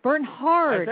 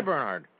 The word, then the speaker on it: Bernhard: Matthew, I've been here 3 months, and you actually mispronounced my last name for the first time. – Bernhard. I say Bernhard.